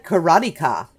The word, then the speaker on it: karateka: It looks like karateka is how it's actually pronounced, and it's just someone who practices karate.